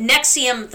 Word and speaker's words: NXIVM: But Nexium, the fucked up cult, is all caps NXIVM. Or if you were to pronounce it like it's spelled, you would scream NVXVMM, I think.